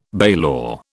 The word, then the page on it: bailor: British English